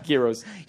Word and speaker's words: gyros: Did you say gurus? – Hang on. – Did you say gurus? – Gurus. – Yeah. – No, gyros. – Gyro, okay. Just get the pronunciation right.